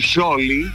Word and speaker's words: Scioli: Mucho que este gobierno tenga en su gabinete a Daniel Scioli. – ¿Por qué? – Por la pronunciación. Me gusta la S pronunciada. Scioli.